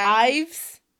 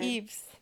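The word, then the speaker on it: Yves: I mean, it's Y-V-E-S, so... – Well, yeah. – Ives? – Eves, because that's how St. Laurent is said. Eves.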